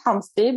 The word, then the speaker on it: Homsey: It is Noelle Homsey, but I'm not expecting you to pronounce the H the way I do.